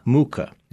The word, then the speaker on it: Mucha: Mucha.